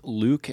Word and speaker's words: Louque: Find me on Twitter at Jake Luke, and my last name is spelled Louque.